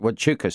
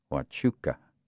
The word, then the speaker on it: Huachuca: Fort Huachuca, sir. – He pronounced that correctly, Castillo thought. Huachuca.